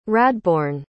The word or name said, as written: radbourne